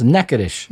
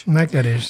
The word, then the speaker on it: Natchitoches: Alors, Natchitoches, si vous le voyez écrit, c'est pas évident que ça se prononce comme ça. – Non, ça s'écrit Natchitoches. – C'est ça, oui, c'est absolument pas... – Ça se prononce Natchitoches.